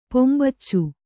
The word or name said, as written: ಪೊಂಬಚ್ಚು